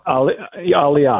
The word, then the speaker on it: Aliyah: So what is Aliyah? Is that how you, or Aliyah? Is that how you say it? – Aliyah. – Aliyah.